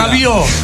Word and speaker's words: Rabiot: Rabiot. – La T no se pronuncia. – Rabiot.